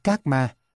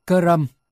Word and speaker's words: karma: Phiên ra như thế thì người Anh, Mỹ đọc là karma, nhưng đúng ra phải phát âm là karam.